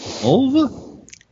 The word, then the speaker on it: Mulva: Mulva rhymes with a body part.